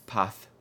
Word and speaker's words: Paz: Path. – Path. – P-A-Z. Pronounced with the Castilian style. Path.